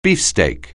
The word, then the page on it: beefsteak: anglais